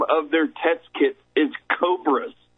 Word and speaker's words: Cobas: Now, I said Cobras because it reads like Cobras. It's spelled C-O-B-A-S. They just took the R of Roche out of the name. It's Cobras, people.